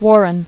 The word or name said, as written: Warren